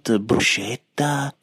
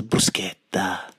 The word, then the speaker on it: bruschetta: It's a hard C, so it is not bruschetta or bullshitta. It is bruschetta.